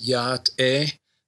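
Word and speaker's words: Yá'át'ééh: and I'm going to do my best to pronounce it, Ya'ate. Ya'ate, something like that. – Yes, Ya'ate. – Ya'ate, sorry. Uh-huh.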